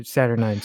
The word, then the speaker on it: Saturnine: Saturnite Terminators. Or Saturnine, excuse me. I always mispronounce that.